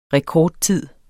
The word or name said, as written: rekordtid